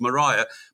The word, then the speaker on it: Mariah: Mariah, and you've pulled me up on that more than once, and said I've got to pronounce it as Mariah.